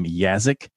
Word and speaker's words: Yaszek: Her name is Lisa, and I think she pronounces her last name Yazzek, Y-A-S-Z-E-K.